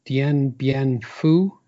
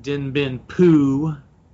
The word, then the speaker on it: Dien Bien Phu: Hi, as a new starter, I did a review of Critical Hits, Advanced Squad Leader, Comp, Dien Bien Phu. Is that how you pronounce that? – Yeah, although LBJ liked to pronounce it Dien Bien Phu.